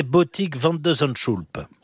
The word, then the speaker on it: Botik van de Zandschulp: Et donc là, en 8e, quel est le niveau de son adversaire, ce fameux néerlandais Botik van de Zandschulp? – Oh, mais vous prononcez très très bien.